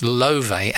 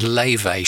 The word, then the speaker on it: Laufey: It's written Laufey, L-A-U-F-E-Y, but I've heard it pronounced Lovey and Lavey.